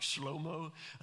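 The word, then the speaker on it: Shlomo (שלמה): Solomon's name is pronounced something like Shlomo, Shalom.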